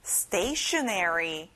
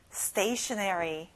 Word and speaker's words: stationery: 학용품, stationary. 앞에 stay에 강하게 말해보세요. Stationary.